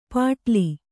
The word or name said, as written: ಪಟಲಿ